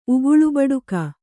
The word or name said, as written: ಉಗುಳುಬಡುಕ